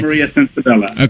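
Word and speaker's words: Censabella: Now that you mentioned theater, tell us about your current project, which is Paradise by Laura Maria Sensabella, am I pronouncing that correctly? – Yes. – Okay. – Maria Sensabella.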